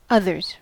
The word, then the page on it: others: American English